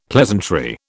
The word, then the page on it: pleasantry: British English